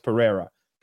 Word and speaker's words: Pereira: Pereira. Pereira is spelled like P-E-R-E-I-R-A, Pereira. But they pronounce it weird because they're, you know, got introduced to English late.